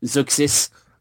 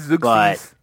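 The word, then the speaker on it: Zeuxis: She's the legitimate belt collector right now when it comes to AEW, winning the CMLL Women's Championship from Zuxis. I'm sure I'm saying that wrong. – It's Zuxis, but it's close enough.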